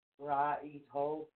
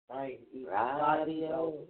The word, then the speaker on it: Raidho: It's actually spelled R-A-I-D-H-O. – Mm-hmm. – But this is the correct pronunciation for you guys. It's R-A-H-E-D-D-H-O. – Rahib Ho? – Yes. – Rahib Ho.